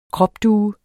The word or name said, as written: kropdue